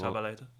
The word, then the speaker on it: Zabaleta: Zavaleta? – Ja. Die stond onder... Zabaleta. Zabaleta? Zabaleta, Zabaleta. – Ja, hij schrijft Zavaleta, maar op zijn Spaans hm. – spreek je dat met een B uit.